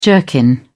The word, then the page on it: jerkin: British English